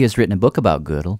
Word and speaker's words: Gödel: I hear it often pronounced, well, I don't speak German, but mathematicians often say it like the woman's piece of clothing, you know, from the old days. But something like Gödel.